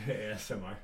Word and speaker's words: ASMR: We still got did I say it right that time? – ASMR. – Yes.